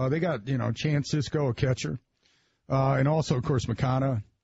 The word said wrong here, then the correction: Moncada: Oh, Moncada? – Yeah.